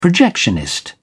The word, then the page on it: projectionist: British English